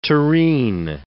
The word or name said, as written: terrine